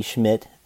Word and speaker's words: Schmit: Schmidt's book, that's spelled S-C-H-M-I-T, Schmidt, in case you were wondering and wanted to look it up.